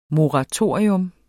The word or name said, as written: moratorium